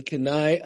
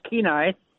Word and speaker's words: Kenai: Hey, we're going to go to Kenai, Alaska. How you doing today? – I'm doing good, and that's pronounced Kenai. – Oh, sorry about that, and, Kenai.